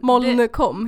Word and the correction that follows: Molkom: heter det så? Molkom. Molkom.